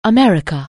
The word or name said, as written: America